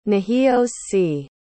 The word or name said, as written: nahios-si